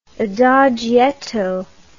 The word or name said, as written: adagietto